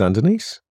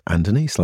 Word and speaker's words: Andonis: Well, my name is Andenice. That is my name. That's the first thing the AI needs to get right, is the pronunciation of my name. It's not Andonis. It's not Adonis. It's not Adonis. It's not all of those things. It's Andenice, like two girls' names put together.